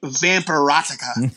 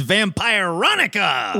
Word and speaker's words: Vampironica: Listen, I'm having a really hard time reading the name of this book and not pronouncing it Vampirotica. – It's Vampironica!